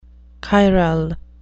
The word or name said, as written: chiral